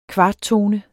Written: kvarttone

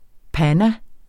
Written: panna

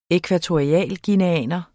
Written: ækvatorialguineaner

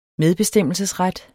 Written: medbestemmelsesret

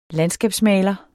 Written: landskabsmaler

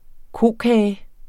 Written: kokage

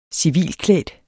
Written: civilklædt

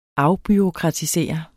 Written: afbureaukratisere

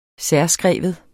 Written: særskrive

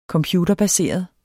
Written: computerbaseret

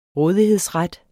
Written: rådighedsret

